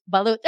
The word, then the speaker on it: Balut: Balut. Oh, there you go. Oh, hey, you can pronounce it right.